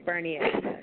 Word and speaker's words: Bernier: I always want to pronounce the R. Bernier, right? – It's Bernier.